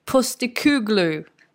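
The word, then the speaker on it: Postecoglou: Alltså det här är ju Skys uttal då, att det är Ange Postikuglu som är ny tränare för Celtic.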